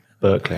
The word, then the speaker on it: Berkeley: I always say that wrong. I always try to say Berkeley. That in a very English accent. Berkeley, I have to say it right.